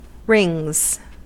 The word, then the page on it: rings: American English